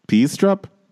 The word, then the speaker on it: Peistrup: Ed Peistrup, P-E-I-S-T-R-U-P, Peistrup, Peistrup, Ed.